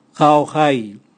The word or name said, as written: gaogai